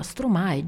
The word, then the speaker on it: Stromae: Ora, Stromae, già il nome, io spero, mi auguro sempre di pronunciarlo bene, ma credo che si dica proprio così, che si pronunci proprio così.